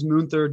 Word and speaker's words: Munther: Yeah, so my name is Munther Dajani with Elumin Agency.